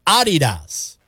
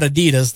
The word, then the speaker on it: Adidas: Number five, Americans pronounce Adidas wrong. I just said it wrong. It's supposed to be Adidas with a slight emphasis on the first syllable, not Adidas, the way we say it here, stressing the second syllable.